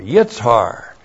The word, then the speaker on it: Yitshar: There, and then the third is Yitzhar. Y-I-T-S-H-A-R, Yitzhar. And Yitzhar is new olive oil.